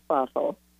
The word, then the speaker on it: Bothell: So did I say Bothell right? Did I get that? – Yes, you did. Yes, it is Bothell. It's funny how differently it can be pronounced.